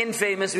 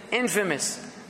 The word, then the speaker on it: infamous: So what did, now we don't notice now in pronunciation, we don't say infamous, we say infamous.